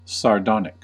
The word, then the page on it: sardonic: American English